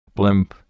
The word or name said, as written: blimp